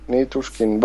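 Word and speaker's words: Nitushkin (Ничушкин): nej men alltså däremot så tror jag, alltså med reservation då, skulle eh, Nikushkin? Är det så man uttalar hans namn? – Nitushkin kanske? – Nitushkin, Valeri.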